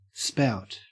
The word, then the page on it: spout: Australian English